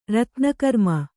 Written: ರತ್ನಕರ್ಮ

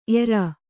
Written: ಎರ

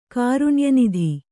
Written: ಕಾರುಣ್ಯನಿಧಿ

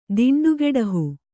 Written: ದಿಂಡುಗೆಡಹು

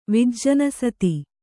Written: ವಿಜ್ಜನಸತಿ

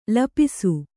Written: ಲಿಪಿಸು

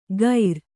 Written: ಖೈರ

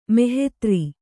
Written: ಮಹೀಧ್ರ

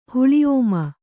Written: ಹುಳಿಓಮ

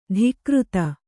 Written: ಧಿಕೃತಿ

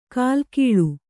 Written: ಕಲ್ಲಿಗೂಳು

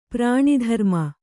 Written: ಪರ್ಣದ್ರುಮ

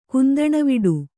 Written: ಕುಂದಣವಿಡು

